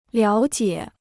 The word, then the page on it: 了解: liǎo jiě